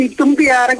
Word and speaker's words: Itumbiara: Itumbiara. Falei certo a palavra? – Agora você falou certo, Itumbiara, Goiás. – Que às vezes eu falo Itumbiara, né? – É na...